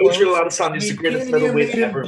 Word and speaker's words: Israel Adesanya: You gotta say it like this. Israel Adesanya is one of the greatest middleweight champions right now.